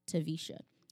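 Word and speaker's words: Taversha: Yes, there is an R in my name, and it's pronounced Tavisha.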